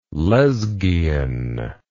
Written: lezghian

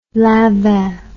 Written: lanvin

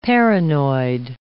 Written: paranoid

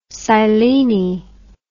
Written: silene

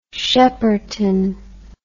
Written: Shepperton

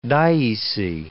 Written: dioecy

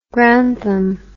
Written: grantham

